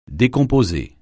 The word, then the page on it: décomposer: French